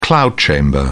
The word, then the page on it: cloud chamber: British English